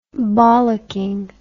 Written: bollocking

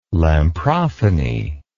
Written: lamprophony

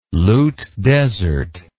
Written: Lut Desert

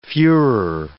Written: fuehrer

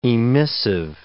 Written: emissive

